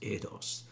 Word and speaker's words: eidos: I believe the Greek is pronounced eidos.